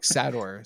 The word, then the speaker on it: Sator: He pronounced Sater like Sator. So he says, "Mr. Sator wants to see you." That must be the Russian way of saying Sater.